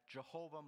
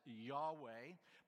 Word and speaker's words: Yahweh: In Hebrew, what's referred to as the Tetragrammaton, YHWH, King James Version a few times translates that specifically as Jehovah. Most people think it's maybe better today pronounced as Yahweh.